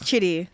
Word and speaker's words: Chidi: It's Chidi.